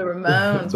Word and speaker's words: Ramones: Did I pronounce that right? The Ramones? Ramones? – The Ramones. Ramones, though.